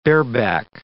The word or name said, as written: bareback